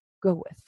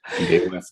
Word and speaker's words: Gawith: Now, it's spelled G-A-W-I-T-H, but it's pronounced Gawith, from what I understand.